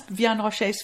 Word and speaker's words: Vianne Rocher: So, Vianne Rocher, and I am so sorry, I'm going to murder all of the French pronunciations. – This is fine, that's, Vianne Rocher's fine.